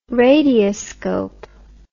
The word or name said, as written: radioscope